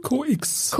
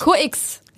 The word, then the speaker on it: CO:X: COX. COX, ja.